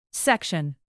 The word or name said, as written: section